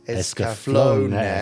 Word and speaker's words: Escaflowne: As I think I mentioned previously, there are no silent vowels in Japanese, hence Escaflowne. Yeah.